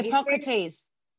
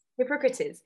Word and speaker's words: Hippocrates: I don't know how you say his name in English. Hippocrate. – Anyway, oh, Hippocrates. – Hippocrates. So it's a quote from Hippocrates.